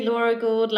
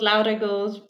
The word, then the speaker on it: Laura Gould: You can say Laura if you want to. Laura Gould, Laura Gould.